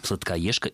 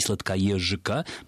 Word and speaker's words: Сладкоежка: То есть получается, что в позиции перед «каф» будет всегда оглушаться, поэтому произносить, конечно, мы будем «Сладкоежка» и «Сладкоежика» произнести просто невозможно русскому человеку.